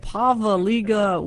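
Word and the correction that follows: Poveglia: What? – It's called Pavaglia. – Ah, Pavaglia. Okay. – A silent G.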